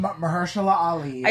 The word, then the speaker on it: Mahershala Ali: Marshala Ali. – Mahershala Ali.